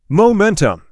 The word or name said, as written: Momentum